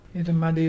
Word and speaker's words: Irrumardi: Itamadi is brim, the fish brim, and it's spelt IRRUMARDI.